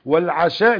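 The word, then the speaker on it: العَشاء: فالعشاء اللي هي الصلاة بكسر العين، والعشاء اللي هو الأكل بفتح العين.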